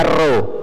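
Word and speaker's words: rho: Kalau P bacanya apa? Bacanya ro.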